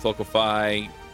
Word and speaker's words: Locofy: Locofy.